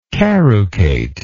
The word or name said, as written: carucate